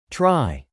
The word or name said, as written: try